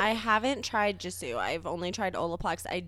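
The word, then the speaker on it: Gisou: There's also one from, I believe it's pronounced Jisou.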